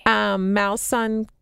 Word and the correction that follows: Maison: No, I don't even know if I'm pronouncing these correctly. So Malson Givas. – Oh. Maison Givas, that is great stuff.